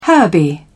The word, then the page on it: herby: British English